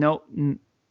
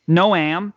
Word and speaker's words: Noam: Noam. – Noam. – So it's like Noah, except with an M.